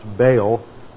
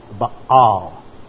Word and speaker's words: Baal: Now, in English, it's usually pronounced Baal, but in Hebrew, it is Baal.